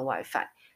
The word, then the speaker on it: WiFi: 结果我才发现说，这边的其实西班牙的咖啡厅提供 WiFi 的真的不多，而且这么市中心的，但是就是很多都没有提供 WiFi。我记得他们的 WiFi 要用西文发音哦，因为西班牙的 I 是发。 e 的音，所以你要念，不能念 WiFi，WiFi 没人听得懂，你要念 Wi-Fi，Wi-Fi 很好笑，Wi-Fi，你就说 I H A Y 是有，然后 I Wi-Fi，然后语言上声音语调上扬就是问问题，你就说 I Wi-Fi 有 WiFi 吗？